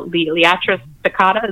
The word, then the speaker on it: Liatris spicata: Definitely the dense blazing star, the Liatris spicata.